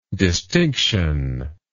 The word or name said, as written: distinctionless